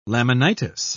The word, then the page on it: laminitis: British English